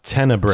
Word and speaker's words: Tenebrae: Apparently, it's pronounced Tenebre. – Tenebre. I heard that in the movie.